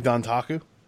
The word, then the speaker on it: Dontaku: Wrestling Dontaku.